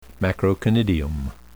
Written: macroconidium

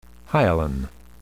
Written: hyaline

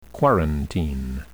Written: quarantine

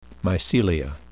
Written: mycelia

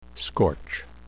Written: scorch

